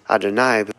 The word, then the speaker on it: Adonai: It's taught that the ineffable name is pronounced as Adonai because Hashem, Adon, master of all worlds and beings, and it's his will that cannot be denied.